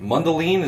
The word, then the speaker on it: Mundelein: Is it Mundelein? Is that how that's pronounced? – Yeah.